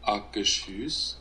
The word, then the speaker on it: Akershus: All right, By the way, I've got the official pronunciation. Hang on, let me see if this works. – Akershus. – There you go. Akershus. I was close. I said Akershus.